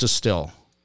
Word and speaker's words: Distihl: It's spelled weird, but it's pronounced distill. D-I-S-T-I-H-L. You know why? Because they're from Normal, Illinois. They don't know how to spell distill. So they spelled it wrong, but they pronounce it correctly.